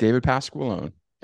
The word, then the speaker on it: Pasqualone: David Pasqualone.